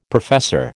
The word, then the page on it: professor: American English